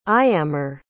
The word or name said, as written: iamar